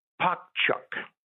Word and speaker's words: Poc Chuc: And one of the dishes that was prepared was pakchuk. That's the way it's pronounced. P-O-C-C-H-U-C.